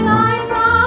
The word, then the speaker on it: Liza: It's Liza with a Z, not Lisa with an S, cause Lisa with an S goes nuts.